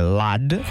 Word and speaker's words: Ludd: Ludd, Ned Ludd, che poi in inglese bisognerebbe pronunciarlo diversamente perché è Ludd.